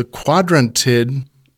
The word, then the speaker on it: Quadrantid: The Quadrantid meteor shower. – You got it. – What perfect timing. – I finally pronounced it, maybe.